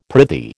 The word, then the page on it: prithee: American English